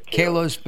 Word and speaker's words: Kalos: The name of your company, okay, Kalos, am I pronouncing that correctly? – Yes. – Kalos Facial Plastic Surgery in Atlanta.